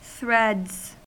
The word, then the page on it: threads: American English